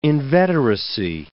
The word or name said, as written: inveteracy